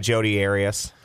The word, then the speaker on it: Jodi Arias: A juror in the Jodi Arias, is that how we say her name? – Yeah, Jodi Arias.